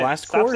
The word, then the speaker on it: Blast Corps: Blast Corps? Stop saying corpse. It's Corps? Blast Corps. – Like Marine Corps. – Yeah, C-O-R-P-S is Corps.